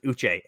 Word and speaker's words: Uche: I keep hearing people say it as Josh Uche, and it's not. Because, and I can tell you that it's pronounced Uche. And you want to know how I know? Because I've interviewed Josh Uche, and if his last name was pronounced Uche, Josh Uche would have actually ripped me in half, because he's a very big man.